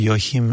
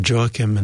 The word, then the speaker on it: Joachim: You may have heard of Joachim and Anna, or Joachim and Anna. Some people pronounce it Joachim.